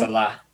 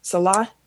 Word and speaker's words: Saleh: Robert Saleh, is that how you pronounce his last name? – Saleh. – Saleh.